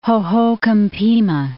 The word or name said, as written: Hohokam Pima National Monument